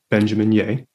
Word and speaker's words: Benjamin Yeh: So it's at Benjamin Yeh.